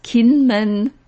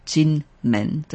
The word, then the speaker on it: Kinmen: Ja, also das ist Kinmen, wird das oft geschrieben. Und die Aussprache oder der chinesischen Zeichen ist eben Jinmen. Das bedeutet, das ist Gold und Tor, wie goldenes Tor, Jinmen. Aber auf den Karten wird das auch oft als K-I-N-M-E-N, Kinmen, geschrieben.